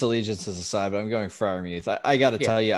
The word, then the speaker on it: Freiermuth: And then a guy, Penn State guy, here with my Penn State boys, Pat Freiermuth. Did I say that right? Freiermuth? – Freiermuth, my boy.